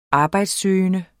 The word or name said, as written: arbejdssøgende